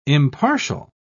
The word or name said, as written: impartial